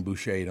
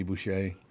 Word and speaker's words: Boucher: You pronounce Henry's last name Boucher, don't you? Henry Boucher, there's a legendary hockey player.